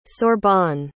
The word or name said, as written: Sorbonne